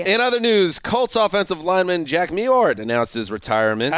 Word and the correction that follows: Mewhort: I think it's Mewhort. – No. That's in Mewhort. – No. Mewhort. The W is basically silent.